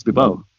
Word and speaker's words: Bebo: It's actually just a pronunciation of my last name. A lot of people are shocked and saying, oh, that's how you say your last name? I'm like, yeah, it's Bebo.